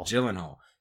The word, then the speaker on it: Gyllenhaal: I mean, first of all, for me, just for me, the fact that Jake Gyllenhaal, I guess, is that how you say it? – Gyllenhaal.